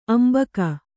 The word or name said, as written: ಅಂಬಿಕ್ಕು